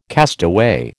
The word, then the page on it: castaway: American English